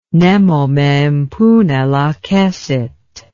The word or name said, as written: nemo me impune lacessit